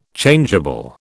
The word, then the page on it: Changeable: British English